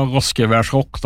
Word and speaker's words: Roskevers Rock: Hur uttalar man det hela, om du ska säga Roskevers, du som kan västgötska? – Ja, det är hela Roskevers Rock då. – Roskevers Rock, ja, bra. – Det är rätt.